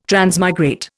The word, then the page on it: transmigrate: Indian